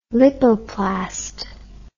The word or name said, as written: lipoplast